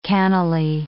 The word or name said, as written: cannily